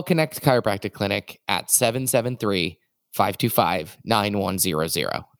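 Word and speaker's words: Knecht: Well, if you're in the Chicago area, Connect Chiropractic. And I realize that we haven't spelled this every time. It's K-N-E-C-H-T. Pronounced Connect Chiropractic.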